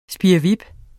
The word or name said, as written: spirrevip